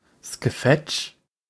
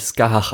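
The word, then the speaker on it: Scáthach: Skefetch? – Nein, wird natürlich Skahach ausgesprochen, aber okay.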